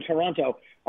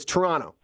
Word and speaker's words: Toronto: We don't pronounce the second T. So it's Toronto. – Toronto. Oh, okay. – So it's not like Toronto.